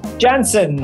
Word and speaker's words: Jansen: Jansen, hard J, cool.